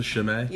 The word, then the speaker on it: Chimay: Is that how you pronounce it? Chimay? – Yeah.